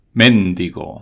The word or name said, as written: mendigo